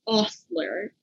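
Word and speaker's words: hostler: And I think me and Brittany and Indy all pronounce it slightly differently, but it's H-O-S-T-L-E-R. And the audio pronounces it Ostler. And I was like, wait, the H is silent.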